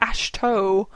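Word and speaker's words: Ahsoka: She said, like, Ashto. – Yeah. – And I was, like, heartbroken. – The correct pronunciation.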